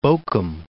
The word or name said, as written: Bochum